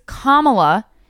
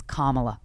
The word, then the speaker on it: Kamala: And then somebody else on Fox was like, it's Kamala. Can you please pronounce her name the right way? It is respect. And the Fox News guy was like, respect, Kamala, Kamala. Guys, it's Kamala. Comma? Hi, comma, how are you? Maybe that's not where a comma goes. But you know what I mean. Comma and then la.